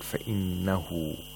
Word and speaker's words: فَإِنَّهُ: We have إِشْبَاعَ of هَاءُ الضَّمِيرِ, and the word فَإِنَّهُ, the ha has to be pronounced like a long vowel because it's preceded by a letter with a haraka, with a short vowel.